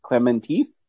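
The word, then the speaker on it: Klementieff: I don't want to mispronounce her last name, but I want to say Clementif. – Yeah, sounds right to me. That's what I would say, Clementif.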